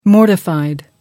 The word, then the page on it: mortified: American English